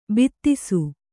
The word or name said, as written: ಭಾತಿಸು